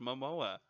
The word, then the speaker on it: Momoa: Jason Mimosa is chicks dig Jason Mimosa. Like... – Mimosa? – What's his name? – Momoa? – Samoa? – No, it's just Momoa. – Momoa? That's Jason Momoa.